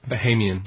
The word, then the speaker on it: Bahamian: Bahamian? Is that how you say it? – Bahamian. – Bahamian. See, I knew I'd get it wrong.